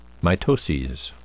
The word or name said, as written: mitoses